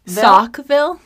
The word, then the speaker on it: Saukville: Saukville. Like I put on my socks this morning. – Sure, but it's spelled S-A-U-K because it's fancy and bougie.